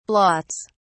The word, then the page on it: blots: British English